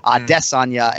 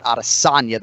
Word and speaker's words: Adesanya: He's emphasizing that second syllable, Adesanya, and not Adesanya, the third one.